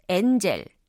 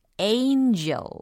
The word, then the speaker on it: angel: 어, 이거 어떤 분들이요. 엔젤. 엔젤 이렇게 발음을 하시는 분들이 계신데 우리 어, GM p e r s 여러분들은 세련되게 또 고급스럽게 원래대로 발음을 하셔야 되겠죠. 엔젤이 아니라 angel. angel.